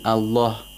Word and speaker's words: Allah: Because actually, in Allah, lam can be pronounced in two ways. – Yes, that's right. The name of Allah, subhanahu wa ta'ala, could be pronounced in two ways. The lam of the majestic name of Allah. either with تغليل, which means thickness. Or ترقيق, with ترقيق, which is thinness. في سبيل الله. Whenever there is a كسرة before the name of Allah, the lam is pronounced with ترقيق.